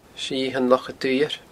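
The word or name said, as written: Sithean Loch a Dhuibhir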